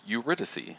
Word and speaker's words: Eurydice: Well, it's apparently pronounced Eurydice.